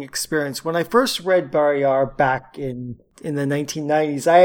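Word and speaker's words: Barrayar: I've always said Barriar. I don't know what the audiobooks do. – The audiobooks pronounce it Barriar. – Then I shall do so forthwith. Barriar.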